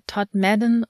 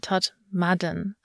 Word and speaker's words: Todmorden: Ja, je nach Dialekt entweder Todmorden oder Todmorden. Aber also dann ist das Mysterium auch gleich weg, aber geschrieben wird's Todmorden und ich finde das irgendwie süß.